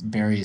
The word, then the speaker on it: Barry: And that's Barry is spelled B-A-R-R-Y.